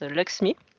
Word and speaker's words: LXMI: Ça s'écrit LXMI, mais ça se prononce Luxmi.